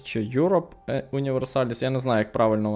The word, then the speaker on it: Europa: Я думаю, що то на грецький лад Європа.